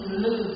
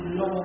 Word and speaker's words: lư: Miền Bắc chúng ta đọc là lư hương xạ nhiệt, còn miền Nam thì tiên nâng và đọc là lô hương xạ nhiệt.